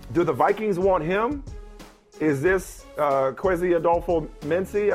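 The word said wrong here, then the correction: Mensah: I hope I got his name right. – Mensa. – The new GM. – Mensa, thank you.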